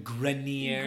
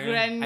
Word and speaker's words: Grenier: Adrian Grenier. – Grenier. – I think it's Grenier. Like it's like shampoo or something.